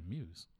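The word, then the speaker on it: Mews: And Muse, in case you're wondering, like, is Mac just mispronouncing? It's M-E-W-S. – Okay. – That's her name, Muse.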